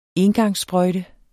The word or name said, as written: engangssprøjte